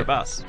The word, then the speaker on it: Kiribati: Kiribati, just because his typewriter didn't have a key.